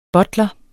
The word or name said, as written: BUTLERS